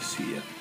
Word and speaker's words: Sia: viene accompagnato dalla voce di Sia, mm, come vogliono pronunciarla in americano, per me è Sia.